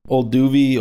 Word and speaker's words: Olduvai: Did you have it written down O-L-D-U-V-A-I. – Here? Olduvi, Olduvai.